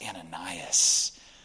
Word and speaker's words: Ananias: I can't remember what, because all I remember him coming up to me, he goes, hey, by the way, it's pronounced Ananias.